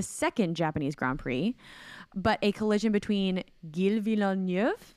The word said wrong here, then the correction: Gilles: Uh, no, uh, it's a soft G, so it's Gilles.